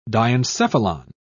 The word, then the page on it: diencephalon: British English